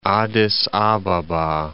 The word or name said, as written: Addis Ababa